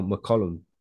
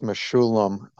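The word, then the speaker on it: Mechoulam: He asks, can talk a little of his experience working with the late, great Raphael McCollum, RIP? Yeah. – Sure. It's actually pronounced Meshulam.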